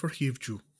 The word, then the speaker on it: Hivju: This time with how am I pronouncing it? Kristofer Hivju. – That is just, your Norwegian is absolutely fantastic.